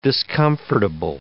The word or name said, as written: discomfortable